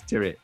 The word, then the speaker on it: Tyrwhitt: It's T-Y-R-W-H-I-T-T, Charles Tyrwhitt. So there you have it. Anyway, Chris, you could do with that discount code, Gregory, lad. – Last time we talked about Charles Tyrwhitt, I was obviously in a dark place with my own fashion because I just I just started hammering myself, didn't I?